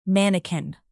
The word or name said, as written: mannequin